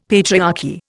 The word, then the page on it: patriarchy: Indian